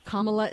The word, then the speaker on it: Kamala: Kamala is pronounced Kamala Harris."